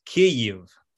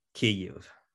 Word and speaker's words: Kyiv: The correct pronunciation, the Ukrainian pronunciation of the city is Kyiv. So Kyiv is the Russian pronunciation, and along with Ukrainian independence, they've put forward the new or the correct Ukrainian pronunciation of the city, which has been accepted in airports and various newspapers and things. So you'll see it now spelled as K-Y-I-V, Kyiv.